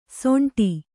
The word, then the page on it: ಸೊಂಟಿ: soṇṭi